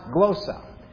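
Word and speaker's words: glossa (γλῶσσα): The word tongues is the Greek word glossa. That's how you pronounce it, glossa.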